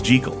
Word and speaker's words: Jekyll: Robert Louis Stevenson was Scottish. The correct Scottish pronunciation of Jekyll is Jekyll.